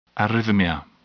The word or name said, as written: arrhythmia